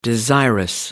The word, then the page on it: desirous: American English